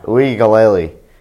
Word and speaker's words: Uiagalelei: Uligali wasn't there. How do you say his name? – I think it's Uiagalelei. – Yeah, Uiagalelei.